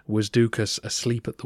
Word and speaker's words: Doukas: For those of you who are binge listening, he does seem to be descended from the same Doukas family who rebelled under Leo VI. And FYI, in Greek that name should sound more like Thukas, but I'll continue with the pronunciation we've established.